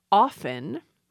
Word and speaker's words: often: That T got lost centuries ago. It also got lost in soften, but we're getting a new spelling pronunciation where speakers are putting the T back in because it's in the spelling.